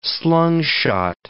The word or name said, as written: slungshot